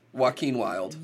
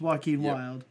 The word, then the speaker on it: Joaquin Wilde: I keep messing up how they pronounce this, but it's... – Joaquin Wilde. – Yeah, it's Joaquin Wilde.